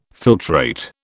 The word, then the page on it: filtrate: British English